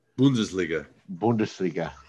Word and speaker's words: Bundesliga: Bundesliga.